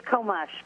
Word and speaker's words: Komush: It's Komush, but it was close enough.